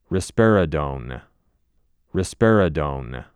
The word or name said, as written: Risperidone